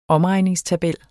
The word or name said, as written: omregningstabel